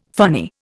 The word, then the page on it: funny: Indian